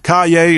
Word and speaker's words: Caillier: Caillé is spelled Caillier.